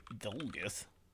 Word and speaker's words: Dolguth: Today, October 20th, we have Dulgeth. – Dulgeth? – What the hell is Dulgeth? – I don't know. – Dolguth. – How do you spell that? – D-O-L-G-U-T-H.